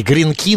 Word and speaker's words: гренки: Ну, вот правильно говори, гренки, но меня прямо это раздражает ударение.